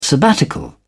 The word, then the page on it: sabbatical: British English